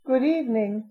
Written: good evening

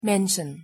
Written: mention

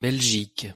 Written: belgique

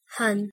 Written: hon

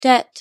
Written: debt

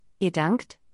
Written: ihr dankt

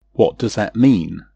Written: what does that mean

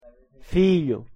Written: filho

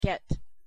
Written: get

